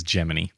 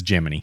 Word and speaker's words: Gemini: Are you looking at me because you think it's Gemini? – Mm-hmm. – Yeah. The official NASA pronunciation is Gemini.